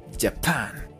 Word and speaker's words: Japan: Pero recuerden, la J suena como una Y. Japan.